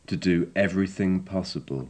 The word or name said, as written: to do everything possible